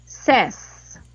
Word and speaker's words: says: Cuando yo digo en tercera persona, fíjense cómo pronuncio. I say, you say, he, she, it, says. – ¿Viste? – Ajá. Says. Uh-huh. Esa es la pronunciación correcta.